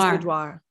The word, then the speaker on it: boudoir: It is boudoir.